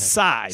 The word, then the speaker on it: Tsai: Oh, I thought it was Kai. That's how I was pronouncing it in my head. – Tsai, C-A-I, right. Like T-S.